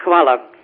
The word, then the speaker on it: Hvala: Hvala. – Fala.